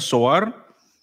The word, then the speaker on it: Zoar: Versículo 30 dice, tiempo después dice, Lot abandonó Zoar, lo estoy pronunciando como está en la Biblia, Zoar,